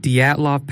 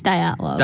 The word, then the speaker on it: Dyatlov: Today we're talking about the Dyatlov Pass. Did I say that right? – I think it's Dyatlov.